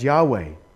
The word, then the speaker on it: Yahweh: which we pronounce in English as Yahweh, a name that was so precious to Jews they wouldn't even say it.